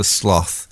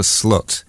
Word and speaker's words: Sloth: Not only is it written Kasper Sloth, but it's apparently pronounced Kasper Slut. So the two gifts, really, it's actually apparently it's like Slut.